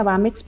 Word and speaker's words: BAMX: Nuestra página, bueno, sé que ya está apareciendo es BAMX, se pronuncia BAMEX pero no lleva la E,